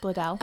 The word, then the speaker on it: Bledel: You had Alexis Bledel. Is that uh-huh. – how you say it? – Bledel.